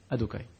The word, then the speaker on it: Adokai: Pourquoi Parce que Yud Kevavke, quand ces quatre lettres sont ensemble, ça ne se prononce pas. Ça se dit Adokai.